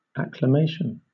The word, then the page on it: acclamation: British English